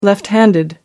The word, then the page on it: left handed: American English